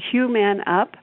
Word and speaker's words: Human Up: And let me spell that for you. It's Hugh-Man space UP calls home. So Human Up.